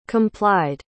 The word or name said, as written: complied